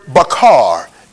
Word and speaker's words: bachar: The word chosen, it is the word bachar. It is spelled B-A-C-H-A-R, B-A-C-H-A-R. It is the word bachar. Watch this, this is powerful. It means join, J-O-I-N.